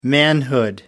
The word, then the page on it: manhood: American English